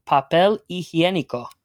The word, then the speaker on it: papel higiénico: It's a noun, and it is el papel higiénico.